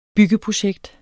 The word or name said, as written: byggeprojekter